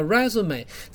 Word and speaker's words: résumé: 所以各位听得出来 resume 很标准，当然你去网络上例句，它上面没有长两根毛，我说最正式的写法是一上面各长一根毛就对了，而且是往上撇呢，叫 resume。这个你就记得往上撇的发音有点像中文的注音 A，好，所以叫 resume，超酷的。